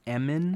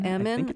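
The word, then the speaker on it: Emin: And with this mogul's son, Eamon, am I pronouncing his name right? – I think it's Eamon. – Eamon, okay. Eamon.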